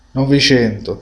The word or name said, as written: 900